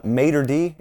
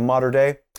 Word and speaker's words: Mater Dei: I may have formerly mispronounced it and called it, what did I call it? Mater D? I used to call it Mater D High School instead of Mater Dei.